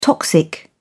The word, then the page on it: toxic: British English